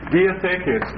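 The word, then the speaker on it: Diatheke (διαθήκη): Diatheke, spelled like this. Diatheke, D-I-A-T-H, long E, K, long E, diatheke.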